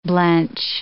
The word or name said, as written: blench